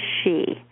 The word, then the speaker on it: Sidhe: and it's pronounced the she, and they are beings that are descended from the stars as we are, but they're more like the fairy beings, and we went more materialistic, but at one time we were the same. We have the same ancestors, and you spell it S-I-D-H-E. So if you look up on Amazon, S-I-D-H-E, I'd never heard of it until about 8 months ago.